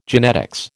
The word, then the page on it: genetics: American English